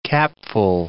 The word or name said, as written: capful